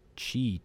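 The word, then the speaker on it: Kyi: And there was this one main character, Sue, I think it's pronounced Chi, to be honest, but it's K-Y-I is how it's spelt.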